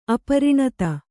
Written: ಅಪರಿಣತ

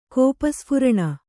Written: ಕೋಪಸ್ಫುರಣ

